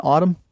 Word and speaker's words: autumn: Did you know there's two silent letters in autumn? That messed me up all morning. – What? Well, I get the N.